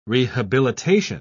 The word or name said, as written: rehabilitation